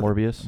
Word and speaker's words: Morbius: Morbius.